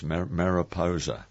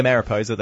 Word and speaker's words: Mariposa: O-P-A-S-A. – Yes, O-S-A. Mariposa. – So it's spelled as it's pronounced, basically. – Yeah. Mm-hmm. You're pretty close there. – Yeah, Mariposa, that's spot on.